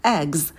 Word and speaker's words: eggs: Eggs. Когда произносим «э», то держим оскал. В конце звук «з» будет звонкий, не оглушайте.